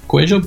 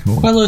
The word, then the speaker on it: Quello: Well, we say Cuello. It's Spanish. – Cuello. – Cuello, but Quello is fine.